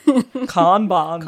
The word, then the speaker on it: Kanban: Kanban is certainly an interesting word, and I'm sure you're wondering where it actually originated from, or the Americans say Kanban or Kanban. – Kanban.